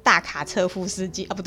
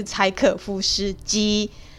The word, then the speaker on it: 柴可夫斯基: 我都念成大卡车夫斯基啊，不对，是柴可夫斯基，他所编就是他所编导编创的芭蕾舞剧。